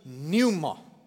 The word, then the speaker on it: pneuma: Eh, in die in die Nuwe Testament, um, in die Grieks is die woord Heilige Gees is die woordjie pneuma met a, met a, met 'n silent p. P N U. E, p N E U M A.